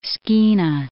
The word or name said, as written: Skeena